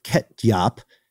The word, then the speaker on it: ketjap: In the Netherlands, there's a sauce called ketjap, uh, K E T J A P. It's an Indonesian soy sauce, but when you pronounce the names, very similar to ketchup.